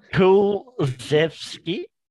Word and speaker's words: Kulusevski: Kulusevski.